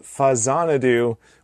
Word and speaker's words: Faxanadu: I've been playing a little game called Fazanadu, which I am told this is exactly how you pronounce it. Don't at me. Because the original game was Xanadu. – Yep. – And it's a Famicom version of Xanadu, so it's Fazanadu and not Faxanadu.